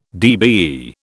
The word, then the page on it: DBE: British English